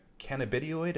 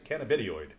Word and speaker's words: cannabidiol: Cannabidiol, I think, is how you said you pronounce that. – Right. – Cannabidiol.